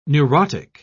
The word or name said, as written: neurotic